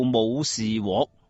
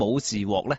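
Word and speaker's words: 武士彟: 她这位父亲啊，叫做武士獲。这个獲字也有点复杂的哈。而且读音方面咧有啲争议嘅，有人啊话读若，有人啊话读獲啊，咁啊暂时我哋读住武士獲先。嗱，武则天呢位父亲武士獲咧，就并唔系普通人嚟嘅，佢系唐高祖李渊喺太原起兵时候嘅从龙大臣，咁亦都算得上系唐朝嘅开国功臣之一啦。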